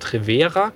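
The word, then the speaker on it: Treverer: offenbar ist die bevorzugte Aussprache tatsächlich, wie wir es gemacht haben, Trevera.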